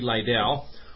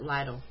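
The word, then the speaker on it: Lidle: And what ended up happening was that it was a pitcher for the New York Yankees named Corey Lytle, who was flying his own private jet, or his own private airplane. – I think they pronounce it Lytle.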